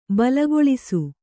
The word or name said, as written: ಬಲಿಗೊಲಿಸು